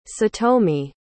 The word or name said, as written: satomi